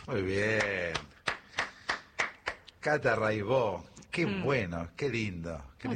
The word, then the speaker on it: Raibaut: Se escribe Raibaut, métanse, búsquenla, Rai con Y, Baut con B larga. Catalina Raibaut, Raibó se dice.